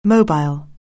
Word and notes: mobile: anglais

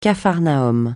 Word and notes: capharnaüm: francais

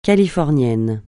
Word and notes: californien: francais

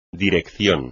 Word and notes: Dirección: espagnol

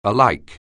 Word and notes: alike: English

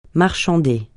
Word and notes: marchander: francais

